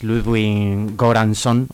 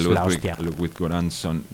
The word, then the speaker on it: Ludwig Göransson: Ah, sí, y el autor, bueno, seguro lo pronunciaré fatal, pero es Ludwig Göransson o algo así, es sueco.